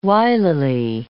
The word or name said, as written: wilily